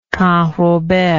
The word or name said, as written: canrobert